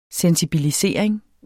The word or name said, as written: sensibilisering